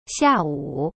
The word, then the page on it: 下午: xia-wu